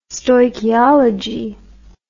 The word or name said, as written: stoichiology